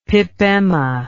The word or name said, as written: pipemma